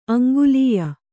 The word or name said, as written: ಆಂಗ್ಲೇಯ